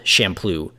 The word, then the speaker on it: Champloo: I think it's pronounced Champuru, but it's spelled Champloo, Samurai Champloo.